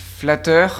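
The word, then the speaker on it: Flattr: Et donc, ce site Internet, ça s'appelle Flatter.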